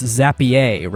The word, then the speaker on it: Zapier: It is it is definitely pronounced Zapier, right? Like it's super classy. yeah, the fancy version. Uh, Zapier makes you happier.